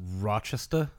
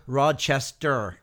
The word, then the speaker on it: Rochester: I, I believe that's pronounced Rochester. – Rochester. – Yeah, Rochester. not Rochester. Like no, it's Rochester.